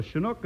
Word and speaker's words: Chinook: It's Chinook. That's C-H-I-N-O-O-K, and pronounced Chinook.